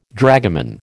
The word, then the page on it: dragoman: American English